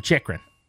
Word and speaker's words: Chychrun: What about Jacob Chychrun? – That's newer. – That's spelled, by the way, C-H-Y-C-H-R-U-N, Chychrun. Jacob Chychrun.